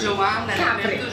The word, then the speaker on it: Capri: Yes, Capri.